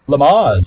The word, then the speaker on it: Lamaze: How do you pronounce? Is it Lamaze? – Lamaze. – Lamaze. Good to know.